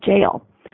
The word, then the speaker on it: gaol: But what's really neat is because it's spelled G-A-O-L, but because of which, hey, we would look at that and go, hey, a dyslexic person wrote goal, right? Is with the hard G, the way they sound it, it actually sounds like jail. So it's Cork County Jail, but it's G-A-O-L.